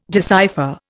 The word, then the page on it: decipher: Indian